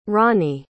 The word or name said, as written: ronney